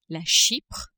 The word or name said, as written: Chypre